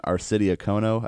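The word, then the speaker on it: Arcidiacono: And then Ryan Arcidi-Okono, I think that's how you say it. – That's right? A good job, because that is a tough name.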